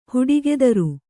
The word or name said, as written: ಹುಡಿಗೆದರು